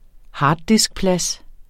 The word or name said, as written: harddiskplads